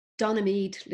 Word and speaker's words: Donaghmede: It's the Donaghmead Literary Festival. And you spell that Donaghmede for people who are not from Ireland.